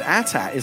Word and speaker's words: AT-AT: I always said ATAT. Is that not correct? Is there? No, it's 100% correct.